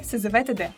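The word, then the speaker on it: СЗВ-ТД: Как это произносить? – Произносится СЗВТД.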